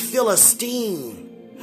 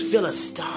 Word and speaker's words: Philistine: Like David said, who is this Philistine? And God corrected me because I grew up hearing the word being pronounced Philistine. No, who is this Philistine? See how mature we must become even when God correct us by saying things correctly.